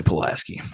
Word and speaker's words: Pulaski: And Pulaski County, for anybody that's wondering, Somerset, Kentucky, is right in the middle of Pulaski County, or Pulaski, however you want to pronounce that. – Pulaski. – Yeah, I say Pulaski.